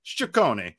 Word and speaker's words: Ciccone: Alec Thomas, Slade Ciccone, by the way, which we are learning to pronounce people's names correctly. Jesse corrected me on this. So I'm going heavy Italian on it, even though I'm not Italian. I'm going to say Ciccone, Slade Ciccone.